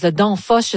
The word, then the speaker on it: Danfoss: Alors, on parle d'une compagnie de logiciels danoise, Danfoss,